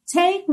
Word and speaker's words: take: Take me by my little hand. Então, take, também você não pronuncia a letra E, right?